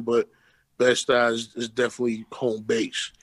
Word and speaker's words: Bed-Stuy: And so you're from Bed-Stuy, right? I don't know if I even said that right. Bed-Stuy, is that how you say it? – Bed-Stuy, no doubt.